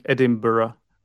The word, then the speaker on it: Edinburgh: Could you do me a favor and pronounce that lovely way of saying Edinburgh? – Edinburgh. – Edinburgh. I wish I could say it like that. – You just need to roll your R's a lot.